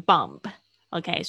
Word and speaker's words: bomb: 还有另外一个字炸弹，b o m b，b 也是不发音的，bomb 不要念成 bomb，OK？